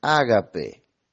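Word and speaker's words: ágape (ἀγάπη): Y esa palabra es esa palabra griega que se pronuncia como ágape.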